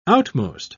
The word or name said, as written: outmost